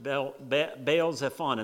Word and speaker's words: Baal Zephon: opposite of Baal Zephon. And that's exactly the right way to pronounce those two places, in case you're wondering.